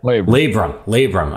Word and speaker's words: labrum: How do you say that? – Labrum. – I'm missing the R.